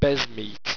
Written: besmeat

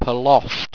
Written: palosd

